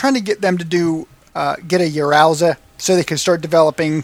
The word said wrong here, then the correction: Yaroze: How do you say that, Shidoshi? I think it's Yaroze. – Yaroze. – So, yeah, because yeah, it's it's Yado is like let's do it and Z is kind of like the exc- exclamation ending.